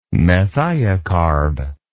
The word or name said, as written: methiocarb